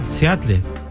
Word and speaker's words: Seattle: O sea, Seattle. – Seattle.